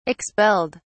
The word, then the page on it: expelled: British English